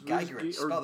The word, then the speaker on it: Giegerich: Yes, Liz Geigrich. Geigrich. – Geigrich, spell that for me. – G-I-E-G-E-R-I-C-H.